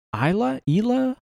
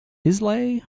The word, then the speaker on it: Islay: Smoother than a lot of the, is it Ila malts? – I don't know. I can't pronounce the Scottish thing. – Who the fuck knows? – I feel like an idiot every time I go to Jig. – Y'all know damn well what I'm talking about. – ILA. – Ila? Islay? – I know I've said it wrong every time I've said it. – Islay is my lay. Islay is your... That's right, isn't it? – I'm not Steven.